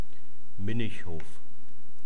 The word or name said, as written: minnichhof